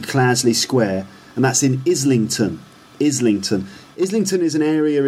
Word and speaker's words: Islington: But he says Islington, Islington, or Islington, because he doesn't pronounce the T sound, just like Islington.